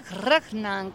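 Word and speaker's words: regnant: Per me reyes regnan, como me decía. Es que cuando hablas en latín clásico, auténtico, se pronuncia así, ¿eh? – Sí.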